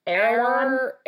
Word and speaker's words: Erewhon: Erewhon.